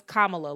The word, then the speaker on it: Kamala: A white family member of mine who was like, Kamala or Kamala, whatever her name is. I said, no. Her name is Kamala. Learn how to fucking say it and say it right.